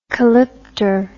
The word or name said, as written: calypter